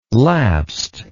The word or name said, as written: lapsed